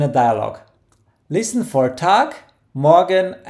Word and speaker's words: Morgen: Morgen.